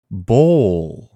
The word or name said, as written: Bowl